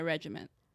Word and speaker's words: regimen: That now it's it's like part of my regimen. – Okay, you're gonna have to show me what this stuff is. Did I say that right? Yeah. Regimen. Yes.